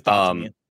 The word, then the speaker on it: Dotemu: I think it looks like Dotemu, like dot emulator, like maybe. – Emulator. That's how I used to pronounce it. Yeah. But Google told me I was wrong. I think it's Dotemu.